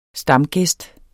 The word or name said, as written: stamgæst